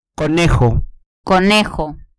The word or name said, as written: conejo